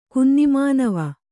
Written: ಕುನ್ನಿಮಾನವ

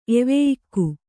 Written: ಎವೆಯಿಕ್ಕು

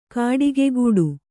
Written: ಗೌಟಿಕೆಗಟ್ಟು

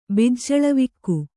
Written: ಬಿಜ್ಜಳವಿಕ್ಕು

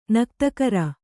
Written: ನಾಗತಗರೆ

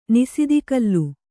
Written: ನಿಸಿದಿಕಲ್ಲು

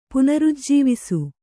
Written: ಪುನರುಜ್ಜೀವಿಸು

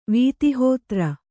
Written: ವೀತಿಹೋತ್ರ

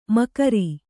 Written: ಮಕರಿ

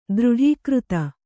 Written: ದೃಢೀಕೃತ

